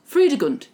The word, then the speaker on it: Fredegund: Fredegund, yes.